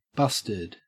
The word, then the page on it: busted: Australian English